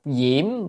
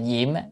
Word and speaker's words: diễm: Độc diễm biến địa, chữ này đọc là diễm à, diễm có nghĩa là lửa, có khi đọc là diệm, diệm nhiên vương Bồ Tát hay diễm nhiên vương Bồ Tát á, à, có khi đọc diệm, đọc âm nào cũng được,